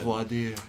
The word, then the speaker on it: voir dire: Well, first of all, they need to know it's voir dire, not voir dire. – Oh my gosh, I've been such an idiot. – I'm kidding, I know. You did it, you pronounced it in the proper Texan.